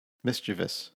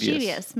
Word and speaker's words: mischievous: Mischievous is totally incorrect. I mean, look at the word and how it's spelled. There's no I here.